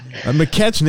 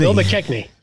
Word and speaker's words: McKechnie: McKechnie. – Bill McKechnie.